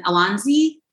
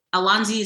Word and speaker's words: Alonzi: I'm listed under Jennifer Anderson Alonzi, and Alonzi is spelled A-L-O-N-Z-I.